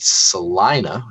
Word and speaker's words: Salina: And Salina, which apparently is how you say it.